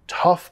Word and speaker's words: tough: Tough parece difícil de pronunciar, muchos vocales ahí, pero solo el sonido a.